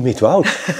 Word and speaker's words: Midwoud: Nou, in Midwoud. Ik ben nu eindelijk gewend om de klemtoon goed te leggen. Want dat is heel moeilijk als je uit Leiden komt.